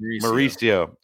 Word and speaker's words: Mauricio: Ronnie Marchuccio, I believe is how you say that. – Mauricio. – Ah, okay. Wow. – Okay. You're close. Close enough.